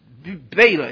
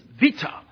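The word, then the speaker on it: vita (βήτα): it's not beta, it's vita.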